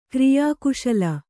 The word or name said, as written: ಕ್ರಿಯಾಕುಶಲ